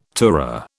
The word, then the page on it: tourer: British English